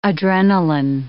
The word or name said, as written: adrenaline